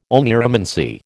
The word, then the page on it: oneiromancy: American English